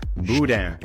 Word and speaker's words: boudin: Boudin. – People see that word, B-O-U-D-I-N, and don't know how to pronounce it. It is pronounced boudin.